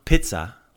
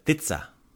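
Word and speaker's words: ptica: Not pizza, like pizza, but titsa. – With a T? – Yeah, titsa. – Titsa. – So it's spelled P-T-I-C-A, P-T-I-T-A, pizza. So like that P is kind of like a, it's like vermouth in a martini. You think about it, but it's not really there.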